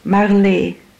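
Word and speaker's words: Marlé: Marley. – And how do you spell that? – M-A-R-L-E, accent aigu.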